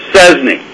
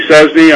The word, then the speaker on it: Szczesny: Yes, it's Szczesny. It's pronounced just like it's spelled. My name's Andy Szczesny.